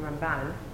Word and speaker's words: Ramban: Rambam and Ramban. But they have the accents on different syllables. So Rambam is always the accent on the first syllable, and Ramban, Nachmanides, is always the accent on the second syllable.